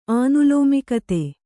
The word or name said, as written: ಆನುಲೋಮಿಕತೆ